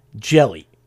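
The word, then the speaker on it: Jelle: I'm going to pronounce this Jelly because it's J-E-L-L-E. – Jelly. – Jelly. You know, like that. He was diagnosed in September with a rare aggressive type of brain cancer.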